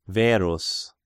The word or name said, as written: Verus